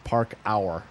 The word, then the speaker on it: parkour: P-A-R-K? – Uh-huh. – P-A-R-K-O-U-R, Park Hour.